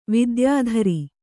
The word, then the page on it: ವಿದ್ಯಾಧರಿ: vidyādhari